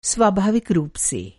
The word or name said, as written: स्वाभाविक